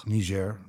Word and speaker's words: Niger: Is that how you pronounce it? – It's Niger. – Niger. – I've been saying it with a hard G all these times.